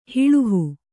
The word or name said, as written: ಹೊಲ್ಲಹ